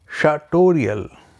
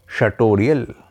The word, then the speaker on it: sartorial: third word सार्टोरियल, spelling है दोस्तों SARTORIAL, SARTORIAL, सार्टोरियल,